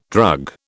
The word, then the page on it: drug: British English